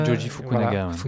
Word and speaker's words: Joji Fukunaga: Et pour finalement, bah effectivement, que le projet atterrisse entre les mains de Fukana... – Joji Fukunaga.